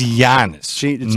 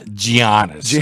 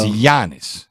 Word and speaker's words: Giannis: His name is Giannis. Not Giannis. I know it's spelled like that. But the G followed by the E sound in Greek is the G is silent, and it's Giannis. – So now the sandwich spelled G-Y-R-O.